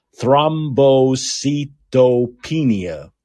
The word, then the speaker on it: Thrombocytopenia: Thrombocytopenia.